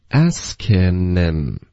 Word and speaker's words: asking him: 这个 asking 的这个音呢， N 的音直接连到 M，就是 him 的 H 又也省略，就发 asking him。